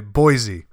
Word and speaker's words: Boise: Did I say that right? Boise. – Yeah, that's right. Boise.